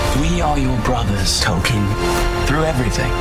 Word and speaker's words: Tolkien: It's pronounced Tolkien.